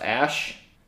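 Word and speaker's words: Æ: and then the AE thing is pronounced Ash, and then the A12 is their, the predecessor to their favorite airplane.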